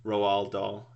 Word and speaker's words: Roald Dahl: Roald Dahl is how you say his name.